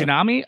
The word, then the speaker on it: Fujinami: I hope I get his name right, but Shinataro Fujinami, Fujiyami, uh-huh. – okay. Fujinami.